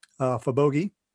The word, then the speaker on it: Fabogi: Cyber Protection Magazine is a cooperative effort between Patrick's company, Fabogi.de.